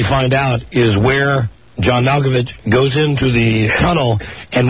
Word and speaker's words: Malkovich: Hey, it's Malkovich.